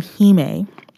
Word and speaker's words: Hime: The name Hime, or the term Hime, it's spelled H-I-M-E. And the E would act as if there's like an accent aigu on it. The accent that goes upwards, like diagonally up, for people who know French.